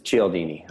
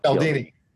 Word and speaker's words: Cialdini: Some it's Cialdini. I always say it wrong. – Cialdini. – Yeah.